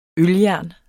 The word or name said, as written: øljern